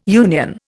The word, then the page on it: union: Indian